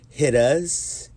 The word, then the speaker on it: hittas: Anybody doesn't know what hit us is, it's called, it's pronounced hit us. H-I-T-T-A-S. Wait, H-I-T-T-A-A. Hit us, like a slang word.